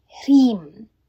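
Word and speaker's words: Hrim (ह्रीं): This is your Hrim practice, the Divine Power Activator Frequency. First, let me demonstrate the pronunciation. Hrim, Hrim, Hrim. Notice the slight roll of the H rhyming with cream.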